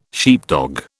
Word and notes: sheepdog: British English

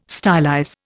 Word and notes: stylize: Indian

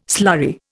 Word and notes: slurry: Indian

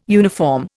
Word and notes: uniform: Indian

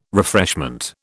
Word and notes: refreshment: British English

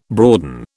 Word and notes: broaden: British English

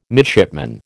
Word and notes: midshipman: American English

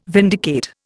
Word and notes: vindicate: Indian

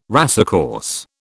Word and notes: racecourse: British English

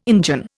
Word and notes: Injun: Indian

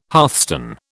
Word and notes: hearthstone: British English

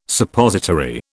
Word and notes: Suppository: British English

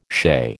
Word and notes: shea: American English

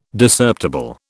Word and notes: Discerptible: British English

